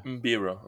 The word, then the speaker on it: Mbira: It's called a mbara. Mbara? How do you say that? How do you pronounce that? It's from Zimbabwe. – Kalimba. – Kalimba. – Oh, what the fuck? – Obviously. Yeah, you've seen that before. – Mbira. – Mbira? Mbara? Mbira? – Oh, I don't know.